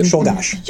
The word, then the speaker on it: Czolgosz: And I'm almost sort of leaning towards Merle's solution only because my son just played Leon Czolgosz in a production of Assassins. – Was that close? Was that really the right pronunciation? – Yeah, Czolgosz. – Yes.